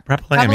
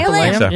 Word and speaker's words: Propylaeum: Means it at the Propylaeum. How do you say that? – Propylaeum. Yeah. – Propylaeum.